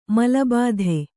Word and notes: ಮೂಲಭೂತ: mūla bhūta

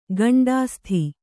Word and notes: ಗಂಡಾಸ್ಥಿ: kannada

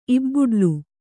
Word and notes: ಇಬ್ಬುಡ್ಲು: ibbuḍlu

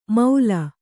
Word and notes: ಮೌಲ: Kannada

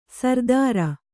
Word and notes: ಸರ್ದಾರ: sardāra

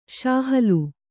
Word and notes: ಶಾಹಲು: kannada

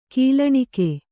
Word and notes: ಗೊಲ್ಲಣಿಗೆ: gollaṇige

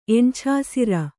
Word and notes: ಎಣ್ಛಾಸಿರ: kannada

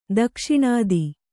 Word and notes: ದಕ್ಷಿಣಾದಿ: dakṣiṇādi

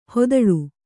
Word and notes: ಹೊದಳು: hodaḷu

